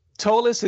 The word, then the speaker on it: Tolis: Well, I don't know if I'm that young, but first of all, Tolis, it's spelled T as in Tom, O-L-I-S as in Sam.